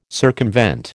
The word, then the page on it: circumvent: American English